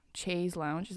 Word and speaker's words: chaise lounge: Chaise lounge, is that how you say it? – Yeah.